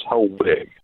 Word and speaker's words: Helwege: It's just Helwege. The last E is silent.